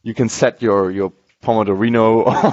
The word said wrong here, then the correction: Pomodoro: how do you pronounce it? – Pomodoro, I think that's Pomodoro. Yeah, I think you're Pomodoro. You're totally right, Max. Pomodoro.